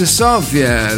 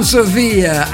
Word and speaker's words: Sofia: Sofia, si dice Sofia, la capitale bulgara. Non Sofia, aia, intelligenza artificiale che ha praticamente che non è compatibile con gli accenti, praticamente.